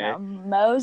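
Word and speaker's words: Mongeau: I didn't know that's how you say your name, first of all. Thought it was like Manju. Or like, it's so weird. Anyway, Mojo.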